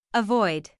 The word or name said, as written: avoid